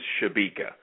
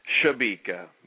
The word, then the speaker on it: Sciabica: The family name is Shabika. – Shabika. – But Shabika. Unlike how the typical American might spell that in Italy, they use S-C-I to make the sh sound. So S-C-I-A-B-I-C-A, Shabika.